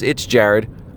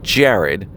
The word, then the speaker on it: Jared: most of the time I get Jerry. Like, no, it's Jared. Okay, John? No, it's Jared.